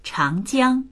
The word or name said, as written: chang2jiang1